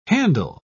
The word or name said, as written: handle